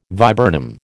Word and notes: viburnum: American English

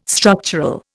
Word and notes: structural: Indian

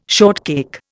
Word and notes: shortcake: Indian

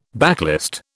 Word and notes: backlist: British English

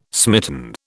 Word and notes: smitten: British English

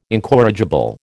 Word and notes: incorrigible: American English

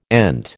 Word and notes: ENT: American English

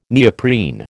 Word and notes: neoprene: American English

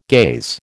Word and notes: gaze: American English

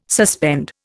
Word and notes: suspend: Indian

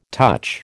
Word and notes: touch: American English

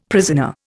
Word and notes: prisoner: Indian

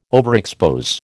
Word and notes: overexpose: American English

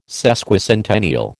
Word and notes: Sesquicentennial: American English

